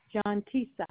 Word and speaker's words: Johntasia: It's spelled J-O-H-N-T-A-S-I-A. – Jontasia.